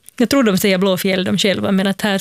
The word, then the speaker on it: Blåfjäll: Blå, det som folk brukar tendera att uttala Blåfield, alltså blå, alltså en F-I-E-L-D. Ja. För att det är en gammal stavning av ordet fjäll. Så det namnet är Blåfjäll.